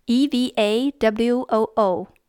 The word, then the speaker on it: Eva Woo: Probably easiest to reach me on Twitter at my name, which is spelled at E V A W O O.